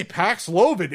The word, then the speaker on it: Paxlovid: But the marketing department that was probably doing high fives when they said, hey, Paxlovid, it rhymes with COVID.